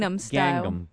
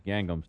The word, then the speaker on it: Gangnam: Is that how that's pronounced? – Gangnam Style. – Gangnam Style. – Gangnam. – Okay. – Gangnam. – Gangnam. Okay. In other words, just the way it's spelled.